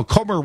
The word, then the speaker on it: Comer: Comer, rhymes with Gomer,